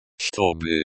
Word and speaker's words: Чтобы: Чтобы. – Stress on the first syllable. CH is pronounced like the CH in church. To rhymes with toe, and by rhymes with be.